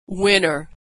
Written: winner

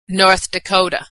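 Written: North Dakota

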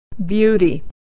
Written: beauty

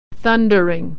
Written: thundering

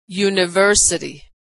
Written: university

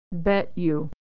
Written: bet you

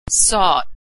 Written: sought

